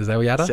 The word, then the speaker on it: Zelyada: It's spelled Z-E-L-Y-A-D-A. So Zell Yada.